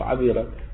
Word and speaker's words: عذيرة: قال والعذيرة الظهر تلفظ بهذا اللفظ عذيرة مثل كلمة.